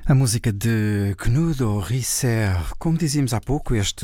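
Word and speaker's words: Knudåge Riisager: Knudo Risser, mas como acontece com outros compositores dinamarqueses, pronuncia-se bem diferente da forma como se escreve, pelo menos para a nossa visão portuguesa. Escreve-se como Knudeg, com uma símbolo por cima do A, mas escreve-se como Knudega Rissager e pronunciam Knudo Risser.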